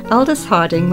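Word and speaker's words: Aldous Harding: Aldis Harding. Have I said that correctly? – Yeah. – Aldis Harding with her song, The Barrel.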